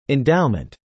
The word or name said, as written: endowment